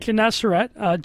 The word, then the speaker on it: Gennesaret: And he and his brother Andrew on Lake Gennesaret. I hope I did not just butcher that name. Sounds right. – Lake Gennesaret.